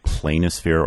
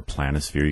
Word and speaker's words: planisphere: Well, I would say a good thing to do is to get a simple planisphere or planisphere. You can say it either way. And they have them, if you just look up that word, it's spelled P-L-A-N-I, sphere, planisphere.